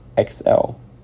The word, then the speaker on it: XL: His brother and best friend is Lex Luthor, or, as his Kryptonian name is pronounced, XL. Get it? It's Lex, spelled backwards, XL.